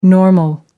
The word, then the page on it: normal: American English